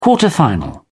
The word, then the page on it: quarter final: British English